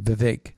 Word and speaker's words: Vivek: I mean, one of the things that was interesting is everybody was attacking Vivek Ramaswamy. That's how you pronounce his name, Vivek.